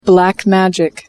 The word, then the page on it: black magic: American English